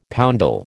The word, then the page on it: poundal: American English